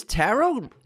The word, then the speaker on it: tarot: Is tarot, is this silent tea? – Silent tea.